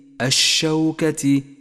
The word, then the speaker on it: الشوكة: اقرأ كلمة الشوكة، الشوكة، ولاحظ أن اللام هنا لام شمسية تكتب ولا تلفظ، وقد لونت بالرمادي إشارة من مصحف التجويد إلى كل حرف لا يلفظ.